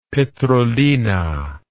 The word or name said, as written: petrolina